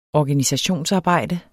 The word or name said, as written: organisationsarbejde